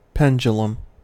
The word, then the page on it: pendulum: American English